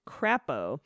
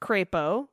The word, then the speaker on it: Crapo: You called the senator from Idaho, Mike Crapo. It's pronounced crepo, like day, and was my grandma's maiden name.